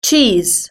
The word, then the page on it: cheese: British English